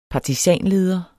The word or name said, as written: partisanleder